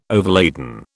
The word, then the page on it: overladen: British English